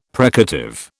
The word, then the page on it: precative: British English